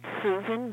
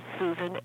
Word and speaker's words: Susan: Okay, it's Susan Bellinger, Susan, S-U-S-A-N. Bellinger, B-E-L-L-I-N-G-E-R.